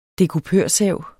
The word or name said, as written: dekupørsav